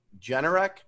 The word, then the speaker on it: Generac: I always pronounce this one wrong. You guys make fun of me, but I'll try to do it right. Generac. GNRC. – There you go.